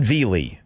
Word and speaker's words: Vele: And then there was another trade of a wide receiver, a guy by the name of Devon Vele. – I've been pronouncing it Vele in my updates just because it sounds right. – Well, Vele is like high class. You know, it's like filet.